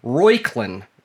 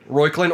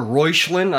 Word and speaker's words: Reuchlin: The firstborn was actually Royklin Wright. – Royklin. – Royklin or Reuchlin, I've heard it pronounced as well, because it's C-H-R-E-U-C-H-L-I-N. Royklin, I believe is how it's glad that name didn't stick around.